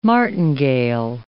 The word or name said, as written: martingale